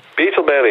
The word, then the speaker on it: Beetle Bailey: In het Engels, help me even, Beetle Bailey? – Beetle Bailey, helemaal goed en goed uitgesproken.